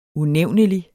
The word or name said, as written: unævnelig